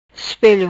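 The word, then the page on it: Ispelo: Patuá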